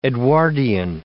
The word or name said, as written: Edwardian